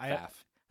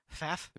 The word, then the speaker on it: Pfaff: I don't know how to pronounce it. Pfaff. – Pfaff. – The P is silent.